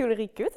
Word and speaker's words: Ulrike: Donc moi, c'est Ulrike, ça vient de la prononciation du premier coup.